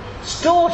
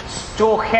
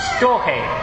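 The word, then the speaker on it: storge: Storch, that's a pretty good go. It's actually Storche, I think.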